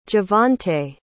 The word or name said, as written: jevonte